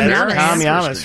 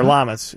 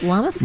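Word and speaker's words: Llamas: Tom Yamas from, or Lamas, as he pronounces it. – Is it Lamas? – Yes, he does.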